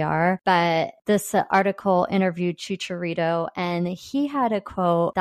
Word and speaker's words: Chicharito: And then my second is I saw this quote about the LA Galaxy from Chicharito.